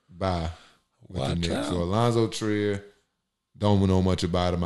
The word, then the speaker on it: Alonzo Trier: Oh, Alonzo Trier. Trier. – Yeah. – We gotta get you like better with pronunciations. We're gonna like pronunciation class. But I think it's Alonzo Trier.